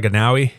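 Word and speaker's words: Anoa'i: Joe Inouye, announced, I guess that's how you say his name, Inouye? – No, that's not it. – I don't know. – It's like Inouye.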